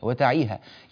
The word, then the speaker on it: وتعيها: كلمة وتعيها نحتاج إلى بيان هذه الحروف 3، تعيا، تاء مفتوحة، عين مكسورة، ياء مفتوحة، لأن بعض الناس ربما التبس عليه بسبب من ينطق من بعض القراء فيقولون كأنه يسمعها وتعيها أذن واعية، وتعيها يعني الياء لم تفتح، فالعناية بفتح الياء وإعطائها زمنها الكامل واجب ضروري ينبغي العناية به.